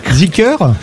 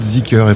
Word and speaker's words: Zeekr: Mais Z2EKR, qui est une marque qui va arriver aussi, vous la prononcez Zeekr. – Alors, ça va être Zeekr, effectivement.